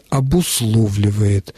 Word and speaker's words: обусловливает: Насчет глагола «обусловливать». Действительно, переход «о» в «а» под ударением в этом, именно в этом слове очень популярен. Он часто встречается даже в письменных текстах. Я всегда это исправляю, если приходится с этим сталкиваться. Правильный вариант «обусловливает». И точка.